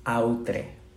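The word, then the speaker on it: autre: Y mencionaba que cuando escribe pone L apóstrofe, y voy a pronunciarlo en castellano, aunque está en francés, outre Que es la palabra en francés para decir otro que se pronuncia mm, más o menos como otro.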